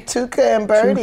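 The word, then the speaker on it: Tuca & Bertie: What's, how do you say this? Tuca and Birdie? – Tuca and Birdie.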